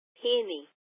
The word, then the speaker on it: Penny: Penny. – Can you spell that for me, please? – P-E-N-N-Y.